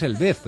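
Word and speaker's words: El Vez: En español es Elvez, pero ya digo, si lo pronuncias en inglés... – ¿Sería Elvis? – Elvis.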